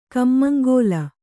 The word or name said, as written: ಕಮ್ಮಂಗೋಲ